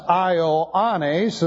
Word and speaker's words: Ioannes: Ioannes, and by the way, the reason it's pronounced Ioannes is because the accent is over the A, alpha.